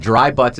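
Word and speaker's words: Dri Butts: Dry Butts, is spell it for it, because it's not... It's the traditional spelling. – Yeah, it's D-R-I-B-U-T-T-S.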